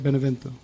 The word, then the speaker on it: Benevento: Benevento? – Yes, yes.